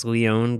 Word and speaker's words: Leone: We learned that his last name is pronounced Leone because we met him in person finally.